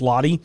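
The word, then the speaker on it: Lotte: I believe it's pronounced Lottie.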